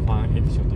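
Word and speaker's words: Fan Edition: FE って書いてファンエディションって読む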